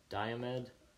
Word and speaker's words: Diomede: Diomede? – I have no idea. – Okay, for the listeners and for our audio listeners here, it's Diomede. – Probably Diomede.